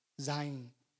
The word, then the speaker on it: Zayin: And the letter that corresponds with is Zayin. Am I saying that right? Zayin. Okay.